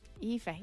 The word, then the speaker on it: Aoife: Why aren't the names spelt the way they're pronounced? It would just make every life so much easier. – E-E-F-A. – Aoife, yeah, exactly. – There you go, Aoife, there you go.